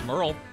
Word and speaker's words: Merle: That's Mama Tried by Merrill Haggard. Anybody know anything about Merrill Haggard? – Yeah, I know that it's Merle.